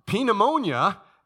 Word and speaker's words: pneumonia: But anyway, pneumonia is, it's with a P, a silent P.